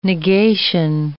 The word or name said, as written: negation